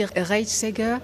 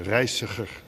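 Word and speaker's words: Reijseger: on dit Reitsäger. – Comme vous voulez. – Non, mais comme... – La prononciation en hollandais, c'est terrible. C'est Reitsäger.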